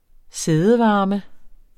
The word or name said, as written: sædevarme